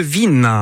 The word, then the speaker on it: Hven: Alors, vous êtes allé sur cette fameuse île, ça s'écrit H-V-E-N, mais on prononce... – L'île de Vine.